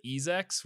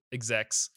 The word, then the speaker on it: execs: I heard other people pronounce it Ezex, which can't possibly be correct.